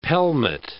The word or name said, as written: pelmet